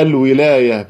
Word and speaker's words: الولاية: طبعا الولاية بفتح الواو الولاية، ولا بفتح اللام؟ هي الولاية بكسر الواو وفتح اللام.